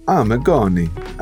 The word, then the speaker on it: Amigoni: But Christina Amigoni, did I say that right? – Amigoni. I do a terrible Italian accent, but there you go.